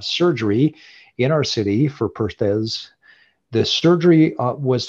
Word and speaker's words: Perthes: Is it Perthes disease? – Perthes. – Perthes disease. All right.